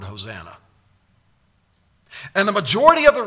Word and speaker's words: Hosanna: You read the word Hosanna in the English, and it's pronounced Hosanna.